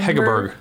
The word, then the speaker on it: Hegerberg: Hegerberg.